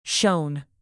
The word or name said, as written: shone